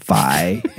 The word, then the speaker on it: phi: But that is true because if you take pi, for example, like Alan mentioned earlier, and Trav realized that pi rhymes with phi and psi.